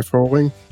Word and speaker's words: Föhring: It's René Föhring.